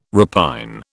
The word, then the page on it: repine: British English